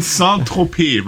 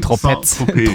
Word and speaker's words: Saint-Tropez: Mord in Saint-Tropez, weil das Z wird nicht ausgesprochen. Mord in Saint-Tropez.